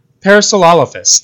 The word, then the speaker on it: Parasaurolophus: So there's two of them so far, one from 2020 called Trans Wizard Harriet Porber and the Bad Boy Parasaurolophus. Yeah, I know that one's hard to pronounce. – Yeah, sorry, I've actually practiced this before the episode, and I just whiffed it there. Parasaurolophus, thank you.